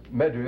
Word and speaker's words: medu: It's pronounced medu. That's a staff, you see. Medu, netu.